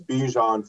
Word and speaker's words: Bijan: It's Bijan Fragrances. It's B-I-J-A-N.